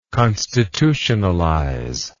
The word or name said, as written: constitutionalization